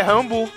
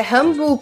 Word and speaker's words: Rambeau: Na verdade, deveria ser Rambu, né? – É Rambu. – É Rambu, porque geralmente quando faz esse E-A-U, é B. – Tá vendo? É Rambu. – É Mônica Rambu.